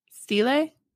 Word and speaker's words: stele: S-T-E-L-E. – Wait, what? – S-T-E-L-E. – Stele? – Is it Stele?